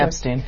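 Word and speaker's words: Epshteyn: Epstein, yeah. – What's the role of Boris Epstein in President Trump's life? – It's a great question because, you know, we have a lot of reporting about Boris Epstein in the book, and he is somebody who we have both followed for a really long time.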